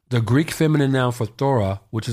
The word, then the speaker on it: phthora: It's pronounced phthora. It's spelled P-H-T-H-O-R-A, phthora.